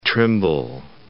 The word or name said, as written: Trimble